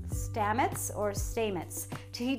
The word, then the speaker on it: Stamets: As world-renowned mycologist Paul Stamets, how do you say that? It's spelled S-T-A-M-E-T-S. Stamets. He discusses, mushrooms can potentially solve some of your most pressing and serious problems.